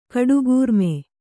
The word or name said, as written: ಕಡುಗೂರ್ಮೆ